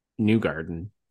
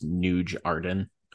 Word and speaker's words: Newgarden: but I've been imagining a guy that pronounces Newgarden is Nuge Arden. I don't know why I've been thinking about that for a long time. So sometimes in my head I say Nuge Arden, but anyway.